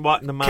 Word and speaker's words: Watanabe: Ken Watanabe.